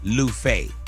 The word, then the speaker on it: Luffeigh: How do you spell Lufe? – L-U-F-F-E-I-G-H. – Yes, it is. First F is silent.